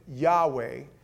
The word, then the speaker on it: Yahweh: The Jews pronounced it Yahweh. Then later on, they added some consonant to it, and it became Jehovah.